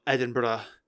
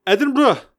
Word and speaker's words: Edinburgh: So I wanted to mix it up on today's episode and talk about my good old city of Edinburgh.